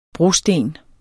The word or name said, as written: brosten